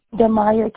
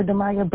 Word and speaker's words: Damiyah: Demaya King or Demaya Burton King. – Spell that first name. – D-A-M-I-Y-A-H. – K-I-N-G for King.